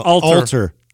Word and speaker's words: Alter: people like that, by the way, Adam, you know you've been pronouncing someone's name wrong for like 3 years. – Who? – Adam Atler. No. – Alter.